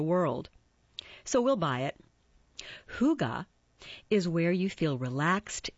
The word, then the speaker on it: hygge: It's pronounced hygge. It's spelled H-Y-G-G-E.